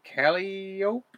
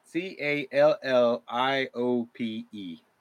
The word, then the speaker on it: calliope: Somebody tell me how to pronounce that. um, let's see. Um, let's see. Oh, it's a calliope. Calliope. – Calliope. Calliope. Yeah.